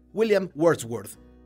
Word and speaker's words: Wordsworth: En la canción de Taylor, observamos un juego de expresiones que hace referencia directa al gran poeta del siglo XIX, William Wordsworth, ella se cuestiona el valor de sus palabras, que en inglés se pronuncia como el apellido del escritor.